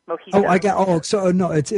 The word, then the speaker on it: mojitos: So the J sounds like an H, Mojitos.